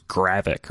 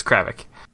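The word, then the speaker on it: Cravic: Yeah, it's C-R-A-V-I-C. – Oh, okay. I couldn't quite tell if it was Gravik or Kravik is all. – I can't remember. So it was a soft A for the first syllable? Okay, then it's Kravik.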